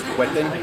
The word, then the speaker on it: Whetten: Sí, mi nombre, primer nombre es Conal, se escribe C-O-N-A-L, apellido es Hueten, W-H-E-T-T-E-N.